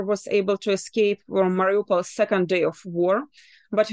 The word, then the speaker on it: Mariupol: You're from Mariupol.